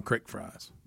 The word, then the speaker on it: crick: And they do say it's listed as creek fries, but it says in Kentucky they call them crick fries.